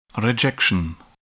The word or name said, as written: rejection